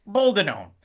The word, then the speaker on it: boldenone: Boldenone.